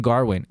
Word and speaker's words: Gawain: I think this is Middle English. I mean, it's spelled Gawain, G-A-W-A-I-N. – I thought they were saying with an R, right? – They kept saying Garwin.